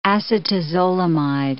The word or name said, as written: acetazolamide